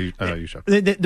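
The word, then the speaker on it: Juszczyk: I like a running back that, I mean, this Yuschef, is that how you pronounce the name? The guy last night? – Yeah, Kyle Yuschef.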